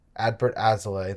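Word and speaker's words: Adbert Alzolay: Is it Adbert Azalea? – Adbert Azalea, that's right.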